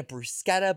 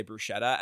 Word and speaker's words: bruschetta: And thank you for saying that properly. – Is that how you say it? – Yes. – Yeah. I work in an Italian restaurant. – I've gone to Italian restaurants where they still say bruschetta, and I'm like, it's not how you say it, doll.